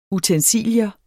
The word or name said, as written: utensilie